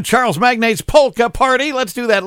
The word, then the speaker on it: Magnate: Charles Magnate's Polka Party is how the name is spelled here, magnately, you know, like he's an oil magnate.